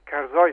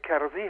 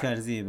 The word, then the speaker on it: کرزی: مثلا ما بسیار اتفاق افتاده که در ایران برای ما هیچ خوشایند نبوده که رئیس جمهور مملکت رو کرزای تلفظ میکنه در اخبار مثلا کرزای، در حالی که یادم کرزای هست یعنی این شخص و نه تنها کرزای بلکه حتی در واقع درستش کرزی هست.